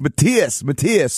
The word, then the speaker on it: Matthias: And of course, not having any idea how to pronounce German names, my whole life I always thought your name was Matthias Jabs. And then the first time I went to Germany, everyone told me, no, it's Matthias.